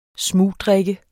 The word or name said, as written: smugdrikke